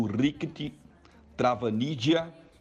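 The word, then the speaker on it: Rirkrit Tiravanija: A gente vai fazer a ativação de uma obra do Rikti Travanidia, que é mais ou menos assim que se pronuncia, tailandês.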